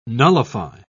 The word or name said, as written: nullify